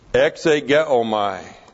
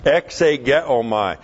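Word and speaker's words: Exegeomai (ἐξηγέομαι): Exegeomai. E-K-S, long E, G-E-O-M-A-I. Exegeomai.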